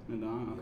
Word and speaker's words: N'dong: Nadan.